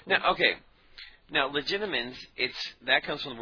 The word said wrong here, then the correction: Legilimens: We're all pronouncing this wrong. It's legilimens with an L. – Legilimens? – Legila, reed. From the Latin legere, reed.